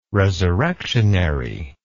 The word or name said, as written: resurrectionary